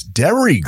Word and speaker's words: Derry: because the way you pronounce it with your Chicago accent is D-A-I-R-Y. – Right. – But then this guy typed, I said, oh, it's Dairy Girls, D-E-R-R-Y. – Yes, Dairy.